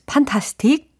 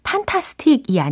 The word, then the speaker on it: fantastic: fantastic이 아니라 fantastic. 어우, 발음이 fantastic 해지지 않습니까? This house is fantastic. This house is fantastic. fantastic 일 줄 알았는데 fan이었고 어, 그다음 에 스트레스가 이름절이 아니라 이음절에 붙죠. 그래서 fantastic 이라고 fanta 이타 부분을 좀 더 크게 발음을 해 주셔야 됩니다.